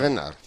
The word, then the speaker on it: Reynard: Diríamos el chalet de Reina. – Chalet Reynard. – Sí, sí, sí.